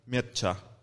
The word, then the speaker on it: Mircea: Miseya. – Yeah. – How do you pronounce it? – Mircea.